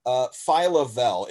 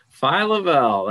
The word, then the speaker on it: Phyla Vell: Phyla Vell. It's spelled with a P-H-Y-L-A. It's kind of one of those weird ones. – Yeah. Phyla Vell, that's our second baseman.